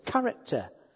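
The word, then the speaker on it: character (χαρακτήρ): It's the word character. And that's how you pronounce it, character.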